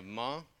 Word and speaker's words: Ma: Uh, we are planning, uh, if we're hoping to go to a spot that's on the edge of the region of Ma. It's called, it's, it's spelled like man, but you leave the N off, and it's Ma.